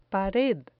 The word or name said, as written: pared